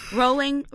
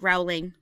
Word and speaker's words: Rowling: Rowling rhymes with bowling, not Rowling. Rowling.